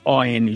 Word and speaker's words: on: Então, a gente vê um on junto, a gente automaticamente nasaliza o O, fala on. The book is on the table. – On the table, Exatamente. E um falante norte-americano nativo, ele não vai nasalizar esse O. Então, ele vai falar o O normal, O, e depois vai pronunciar o N.